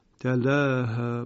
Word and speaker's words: تلاها: یکی آیه دوم والقمر اذا تلاها، اینجا استثناءً در قرائت همزه کوفی بدون اماله تلفظ می شود، یعنی می شود تلاها با فتح.